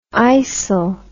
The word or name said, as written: yssel